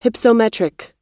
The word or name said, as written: hypsometric